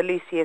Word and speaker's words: Luce: And my name is Lucy Brett, but the Lucy is spelled L-U-C-E and then B-R-E-T-T.